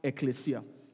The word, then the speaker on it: Ekklesia: But the original Greek word is E-K-K-L-E-S-I-A, and it's pronounced ecclesia.